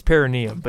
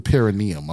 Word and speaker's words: perineum: I believe it's perineum, but yes. – Oh, yeah, yeah, you're right. It is the, it's, the per, it's a perineum. I'm sorry. Yeah, yeah, yeah. That is how you say it.